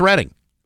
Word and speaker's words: Reading: But yeah, if you look at the spelling, it's Reading. Well, it's not pronounced Reading, it's Reading.